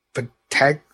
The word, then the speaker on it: Patinkin: But it's Mandy Patinkin. – Patinkin. Yeah. Mandy Patinkin's going to be the voice of Papa Smurf.